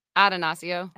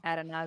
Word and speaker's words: Attanasio: How do you say the last name? Attanasio. Attanasio. – Attanasio.